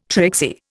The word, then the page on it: tricksy: Indian